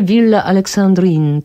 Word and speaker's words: Villa Alexandrine: Ale Villa Alexandrine, to na pewno tak się wymawia i to też przemawia do wyobraźni, zresztą bardzo piękny budynek.